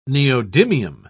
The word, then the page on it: neodymium: British English